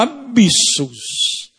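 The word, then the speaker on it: abissos (ἄβυσσος): Eu estou pronunciando na forma mais popular possível do grego koiné, era abissos, abismo.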